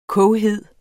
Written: koghed

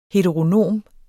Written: heteronom

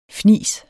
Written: fnis

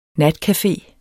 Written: NATCAFE

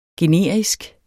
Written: generisk

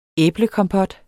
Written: æblekompot

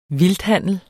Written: vildthandel

